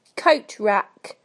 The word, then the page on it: coat rack: British English